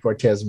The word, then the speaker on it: Cortez: You can never go wrong with Cortez. It sounds just like Cortez. You cannot say it wrong. There we go. Just think either Nike or Hernando Cortez.